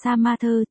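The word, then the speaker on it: sa ma thơ (杀马特): Vô lực phẫn nộ, sát mã đặc cái này tiếng Trung đọc là sa ma thơ, chính là chữ smart trong tiếng Anh đó.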